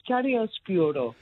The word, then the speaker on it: chiaroscuro: I just remember chiaroscuro.